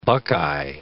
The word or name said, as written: buckeye